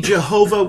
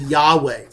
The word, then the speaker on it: Jehovah: When we pronounce it, when we say Jehovah, we're pronouncing that sacred name using the vowel pointing for the Hebrew word Adonai, or Lord. So that when we put the two together, we end up pronouncing it Jehovah. There are people who want to say, well, it's really Yahweh, maybe.